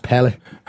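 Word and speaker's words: Pelé: I don't want to be the pedant there, Chris, but I think it's actually pronounced Pele.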